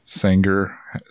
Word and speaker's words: Sanger: Sanger. Is that how you pronounce it? Sanger, California? S-A-N-G-E-R. – Yeah, it'd be in the Sanger Reedy area of California.